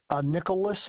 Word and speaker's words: Nickolas: Nicholas Martin.